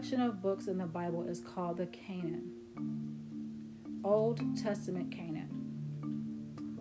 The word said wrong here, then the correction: canon: These three sections make up the entire Old Testament, the New Testament canon. Canon, C-A-N-O-N, that's how it's spelled.